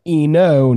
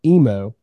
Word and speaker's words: Eno: That's Eno, not Emo.